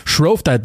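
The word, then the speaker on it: Shrovetide: Es geht um Shrove Tide, wenn ich das richtig ausgesprochen habe. Genau so ist es. Shrove Tide, the world's biggest football match, wie es so schön heißt.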